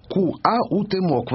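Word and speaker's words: Cuauhtémoc: Молодой и Куауэмоц. Вот так его надо произносить.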